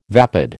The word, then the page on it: vapid: American English